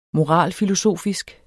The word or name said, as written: moralfilosofisk